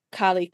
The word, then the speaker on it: Karlee: Same username, which is Carly Karma. So however you think Carly might be spelled, it's probably not. So that's K-A-R-L-E-E, and then Karma as you would spell it normally.